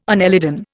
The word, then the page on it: annelidan: Indian